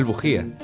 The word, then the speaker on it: Bujía: Que Samuel Bujía, con B y G y acento en la I.